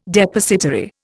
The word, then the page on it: Depositary: Indian